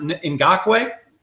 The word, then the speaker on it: Ngakoue: As good a coach as Zimmer is with his defensive orientation, but picking up, thank goodness you pronounce it Ngakoue.